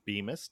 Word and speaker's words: Bemis: Say Anything is the band that was founded by Max Bemis. Did I say it right, Bemis? – Yes.